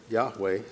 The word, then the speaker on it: Yahweh: So usually when I see that word now, I pronounce it Yahweh, so I may do that today.